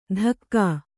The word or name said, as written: ಧಕ್ಕಾ